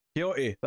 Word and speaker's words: Quixote: Quixote, thank you. I had no idea how to pronounce that, the last name.